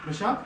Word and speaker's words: Meshach: The name that was given was Meshach. I'm pronouncing it wrong. Meshach, which is who is what Akku is.